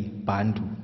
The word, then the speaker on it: Bantu: The name Bantu, which means one for the people, has been used in South Africa in the negative, and it's been used with a hard rather than silent B, Bantu,